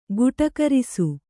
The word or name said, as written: ಗಟುಕರಿಸು